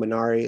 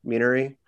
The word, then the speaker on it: Minari: Like I remember first I found out during the Oscars that a movie I've been calling Minari is pronounced Minari.